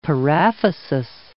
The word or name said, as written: paraphysis